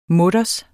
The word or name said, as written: mutters